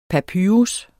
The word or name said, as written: papyrus